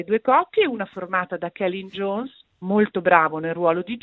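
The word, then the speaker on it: Celyn Jones: La storia è stata diretta e scritta da Celine Jones, che si pronuncia Kellyn Jones, perché lui è un gallese, e da Tom Stern, l'ho scoperto anch'io, guarda, mi è entrato nell'orecchio perché l'ho sentito nominare.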